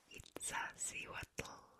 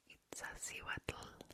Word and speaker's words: Iztaccíhuatl: And I think the volcano that we're going to is called Iztaccíhuatl. Iztaccíhuatl. Iztaccíhuatl. So Nahuatl has a very common noise in the language, which is TL. Pronounce it like t-t-t, like that. T-t.